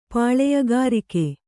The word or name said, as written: ಪಾಳೆಯಗಾರಿಕೆ